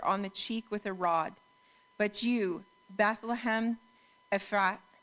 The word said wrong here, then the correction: Ephrathah: I say it wrong every time, "Ephrathah,